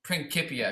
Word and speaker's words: Principia: Principia, if you want to pronounce it.